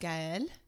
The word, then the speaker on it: Gaël: So my name is pronounced Gaël in French. – Bonjour. – Gaël, and it's really Gaël Le Marchand.